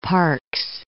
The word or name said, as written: Parks